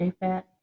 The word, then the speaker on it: Japheth: Noah had Shem, Ham, and Jehoshaphat. Is that right? I said it wrong, didn't I? Japheth, J-A-P-H-E-T. – Shem, Ham, and Japheth.